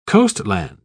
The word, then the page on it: coastland: British English